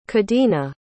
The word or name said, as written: cadena